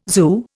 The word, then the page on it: zoo: Indian